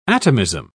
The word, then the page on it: atomism: British English